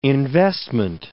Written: investment

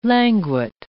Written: languet

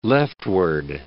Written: leftward

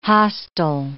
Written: hostile

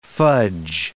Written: fudge

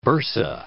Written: bursa

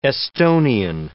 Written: Estonian

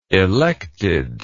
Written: elected

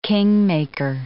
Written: kingmaker

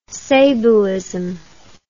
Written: sabaism

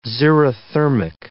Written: xerothermic

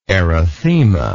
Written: erythemal

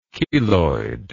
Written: cheloid

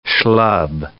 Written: schlub

